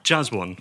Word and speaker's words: Jaswan: As an expert, how would you say your name, Ivor? – Jaswan. That's how it comes out.